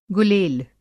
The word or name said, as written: गुलेल